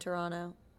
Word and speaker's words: Toronto: I'm from Toronto. – Wait, also, do you pronounce the second T in Toronto? – I do now because, I mean, Americans do. – In Toronto, we say Toronto.